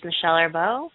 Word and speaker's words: Michelle Arbeau: Twitter is just Michelle Arbeau, and I'd love to hear from you.